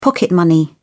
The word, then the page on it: pocket money: British English